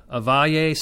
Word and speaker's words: Avaya: But this is, Avaya Stadium is how you say that computer company name.